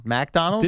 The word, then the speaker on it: Macdonald: MacDonald? – This is spelled M-A-C, MacDonald. – You think it's pronounced MacDonald? – Yeah, I would say MacDonald.